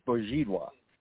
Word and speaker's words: Bezidlo: Bezidlo is the English pronunciation of it. The uh-huh. Polish pronunciation of it is Bozidła.